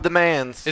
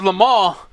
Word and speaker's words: Le Mans: The 24 hour of the Mans. – It's 24 hours of Le Mans. But us white Americans call it like it's spelled.